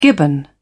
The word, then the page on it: gibbon: British English